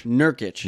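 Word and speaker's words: Nurkić: Joseph Nurkic. – Nurkic.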